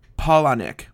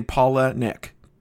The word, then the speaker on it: Palahniuk: How do you say his name? Palahniuk. Like saying Paula Nick.